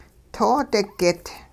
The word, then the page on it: toodigit: hupa